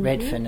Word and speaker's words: Redfern: Redfern.